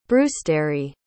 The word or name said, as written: brewstere